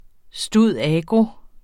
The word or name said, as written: stud.agro.